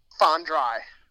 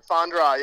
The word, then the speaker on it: Fondrei: And from someone who has a difficult last name like Aquisto, your name is correctly pronounced how? – Fondrei. – Not Fandry. It's Fondrei. – Fondrei, yes, sir.